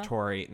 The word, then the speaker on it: Naturi: It's largely sung by Natari. Sorry, am I pronouncing it right? – Uh, Naturi. It's Naturi.